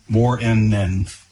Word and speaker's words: Moreinnen: So on the pronunciation guide, it's Mika Moore, M-O-R-E-I-N-N-E-N, Moore-N-N.